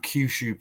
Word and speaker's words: Kyushu: So the company is called Kyushu Pro-Wrestling.